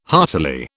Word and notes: heartily: British English

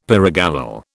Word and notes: pyrogallol: British English

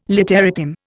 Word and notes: literatim: Indian

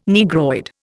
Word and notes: Negroid: Indian

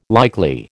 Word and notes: likely: American English

